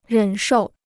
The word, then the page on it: 忍受: rěn shòu